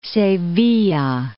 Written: Sevilla

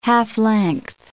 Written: half-length

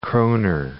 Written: kroner

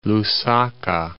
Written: Lusaka

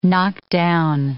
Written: knock down

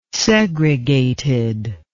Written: segregated

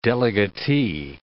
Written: delegatee

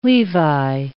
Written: Levi

